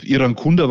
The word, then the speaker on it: Irankunda: Iran Kunda war schon ganz gut ausgesprochen, würde ich sagen. Also es war fast fehlerfrei.